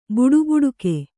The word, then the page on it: ಬಡಬಡಿಕೆ: baḍa baḍike